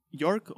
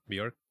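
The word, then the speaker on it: Björk: En esa escena donde sale York o Bjork, o como se pronuncia. – Bjork.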